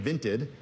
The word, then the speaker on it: Vinted: Vinted. – Can you spell that for me? – Vinted. V-I-N-T-E-D. – Okay. – Vinted. – Like vented with an I.